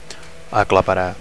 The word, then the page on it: aclaparar: Catalan